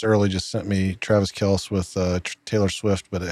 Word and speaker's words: Kelce: It's pronounced Kelce, by the way.